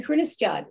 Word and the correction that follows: Kristianstad: isn't it? Am I saying that right? – Kristianstad.